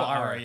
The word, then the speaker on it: Iroh: I feel so bad for Uncle Iroh. Am I saying his name right? – Uncle Iroh, yeah. – Yeah, Iroh.